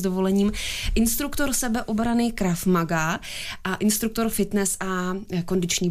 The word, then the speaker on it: Krav Maga: A učíte je to skrze to bojové umění Krav Maga, jestli to vyslovuju správně. – Vyslovujete to správně, ale není to tak úplně bojové umění, jako spíš systém reálné sebeobrany.